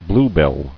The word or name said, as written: Bluebell